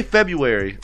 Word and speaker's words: February: How do you say February like the real way? – Like you said it. – But not. That's but it got the extra February. R though. It's not February.